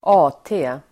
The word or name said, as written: AT